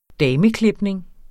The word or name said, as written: dameklipning